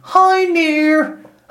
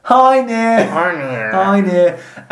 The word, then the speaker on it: Hoenir: Freya, her brother Frey, and Njord of the Vanir went to the Azir and Hoenir, pronounced roughly Hoenir. Hi near.